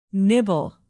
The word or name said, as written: nibble